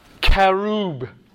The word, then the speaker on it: cherub: Cherub is the word cherub. Right? The CH is k.